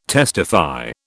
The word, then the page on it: testify: British English